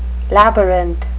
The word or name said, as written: labyrinth